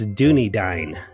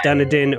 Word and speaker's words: Dunedin: finally, I did find some internet videos to learn the pronunciation of Toronto's single-A baseball team where Vladimir Guerrero Jr. plays, and it's Dunedain. Dunedain. – Dunedain. – Not Dunedin or Dunedin. Yeah, it's Dunedain. So.